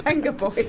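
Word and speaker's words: Vengaboys: Like Benga Boys. – Benga Boys. – That is horrible. – Apparently, it's how you say it, I've been told.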